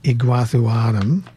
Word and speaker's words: Iguazuanum: So they do flower the same as that group, but they're into specific hybrids like Orlicum crossed with Iguazuanum. I'm going to spell that. I-G-U-A-Z-U-A-N-U-M, Iguazuanum, as in Iguazu Falls.